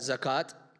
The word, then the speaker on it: zakat: How do you say that? – Zakat.